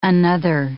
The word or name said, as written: another